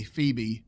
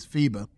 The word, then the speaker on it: Phoebe: I know some people say Phoebe, but supposedly the pronunciation is Phoebe.